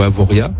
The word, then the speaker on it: Avoriaz: Au niveau mercure, il faut compter cet après-midi 17 à Avoriaz ou Avoria. – Avoria, merci. – On ne prononce pas le Z à la fin.